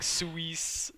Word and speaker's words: Suisse: Do you know how to pronounce that? – Uh, no, uh, like Suisse. Suisse. Like so, the Credit Suisse Technology know.